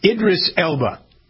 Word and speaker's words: Idris Elba: I never pronounced this guy's name right. Idris Elba. – Yeah, perfect.